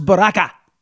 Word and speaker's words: Baraka: A guy named Roz Baraka rhymes with caca.